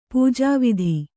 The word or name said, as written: ಪೂಜಾವಿಧಿ